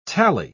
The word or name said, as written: tally